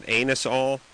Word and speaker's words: Anusol: And by the way, Anusol, shouldn't you pronounce that anusol? – It's A-N-U-S-O-L.